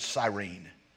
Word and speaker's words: Cyrene: Well, we know from the accounts in all the Gospels, we know that he was from a place called Cyrene, and some people pronounce it Cyrene.